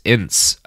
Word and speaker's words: Enss: Um, so, my main source for this episode is Ma Barker, America's Most Wanted Mother by Howard Kazanjian and Chris Enss, uh, E N S S. I don't know how to pronounce that. Enss seems to be right.